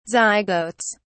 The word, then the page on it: zygotes: British English